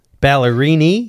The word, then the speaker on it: Ballerini: Back to Kelsey Ballerino. – Yeah. What is the pronunciation? Ballerini. – Yeah, ends in an I, not an O.